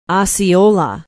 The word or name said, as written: osceola